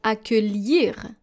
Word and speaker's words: accueillir: Let's go to our second word, which is very close, and it is spelled A-C-C. U-E-I-L-L-I-R. Encore une fois, A-C-C-U-E-I-L-L-I-R. And this is actually the verb to welcome. And this is how you are supposed to pronounce it, accueillir. Accueillir, and not accueillir.